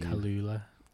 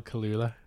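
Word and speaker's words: Kaluuya: I can't pronounce his second name. Daniel Oloway. – Kalula. – I just got Daniel it way wrong. Daniel Kalula. That was shocking. – Oh my God. Yeah. Daniel Kalula.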